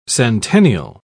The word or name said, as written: centennial